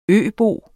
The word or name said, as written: øbo